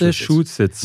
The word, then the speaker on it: Massachusetts: Genau, pass auf, kleiner Trick. Messe, Chu, Sitz. Massachusetts. Massachusetts.